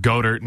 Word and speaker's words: Goedert: I've heard Goedert and Goddard. Yeah. – Goddard. Dallas Goddard. I think it's Goddard. Yeah. Dallas Goddard.